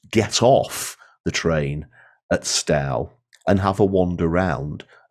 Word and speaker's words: Stow: It's spelled S-T-O-W. There is a Stow down in England, but the one in Scotland, this one, is actually pronounced Stow.